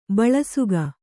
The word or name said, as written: ಬಳಸುಗ